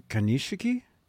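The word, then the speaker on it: Konishiki: And legendary sumo wrestler, how do you pronounce his name? – Kanishiki? – Kanishiki? – Yeah.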